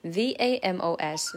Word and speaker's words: vamos: V A M O S, vamos.